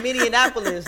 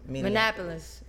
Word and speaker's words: Minneapolis: Because at first I was saying Minneapolis, like Indianapolis. I was like Minneapolis, but it's Minneapolis. – It's not Minneapolis.